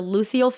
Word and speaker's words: luteal: I had people commenting, girl, it's the luteal phase.